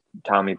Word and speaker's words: Tommee: So all of my socials and website, everything is just Tommee Profitt.